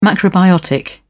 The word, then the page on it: macrobiotic: British English